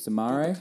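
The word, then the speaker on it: Soumaré: Another 22-year-old defensive midfielder, Soumare. Is that how you pronounce it? – I think it's Soumare. Bukari Soumare. – Soumare.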